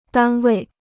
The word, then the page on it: 单位: dān wèi